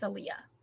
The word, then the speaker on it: Aaliyah: I don't think that's how that's pronounced. I think it's Aaliyah.